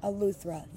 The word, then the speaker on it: Eleuthera: Well, the Bahamas, we're in Spanish Wells, so we're right near like Harbor Island and right off Eleuthera.